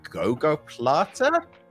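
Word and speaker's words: gogoplata: Next up, I am going to get shouted at for getting the pronunciation of this wrong, but a Gogo Plata? – That's it. – Gogo Plata.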